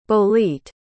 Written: bolete

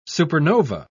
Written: supernova

